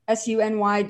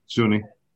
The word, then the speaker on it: SUNY: And then he completed the prestigious oral and maxillofacial surgery residency program at SUNY Downstate Medical Center. Am I saying that right or should I say Sunny? – Sunny. – SUNY. – Yeah.